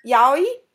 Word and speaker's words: yaoi: And I guess, you know, I also tried, you know, like reading I never know how to pronounce it. Waoi? Yaoi? – Yaoi? – Yaoi? Yeah, that one.